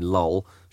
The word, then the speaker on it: Jol: But yeah, I think it's hard to dislike Martin Jol. Martin Lowell, should I say.